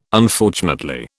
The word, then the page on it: Unfortunately: British English